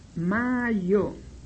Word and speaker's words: majo: Ejemplo, mayo se escribe en esperanto majo, M-A-J-O, como le están oyendo. Majo se lee mayo, mayo. Se escribe como lo acabé de oír, repito, majo, pero, como acabo de decir, la J castellana se pronuncia siempre como la Y.